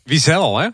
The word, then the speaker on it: Wiesel: Ja, de klemtoon zou ik iets anders zeggen. – Reine Wiesel. Wiesel, hè? – Wiesel, ik wilde het al zeggen eigenlijk.